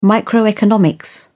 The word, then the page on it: microeconomics: British English